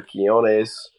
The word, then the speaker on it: Quiñones: Lester Quiñones, or Quiñones. – I think you said it right the first time.